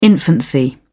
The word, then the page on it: infancy: British English